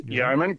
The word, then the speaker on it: Yeoman: Now, this 1958 Chevy Yeoman, is that how I'm saying it right? Yeoman wagon? – Yeoman, correct.